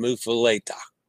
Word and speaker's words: Muffuletta: Muffuletta. I pronounced that one wrong too. I keep learning. Muffuletta.